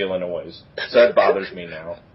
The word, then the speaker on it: Illinois: Is it Illinois? – It's Illinois. The S is silent.